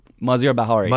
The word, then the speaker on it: Maziar Bahari: Maziar Bahari. – Maziar.